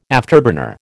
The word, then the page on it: afterburner: American English